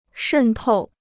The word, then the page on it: 渗透: shèn tòu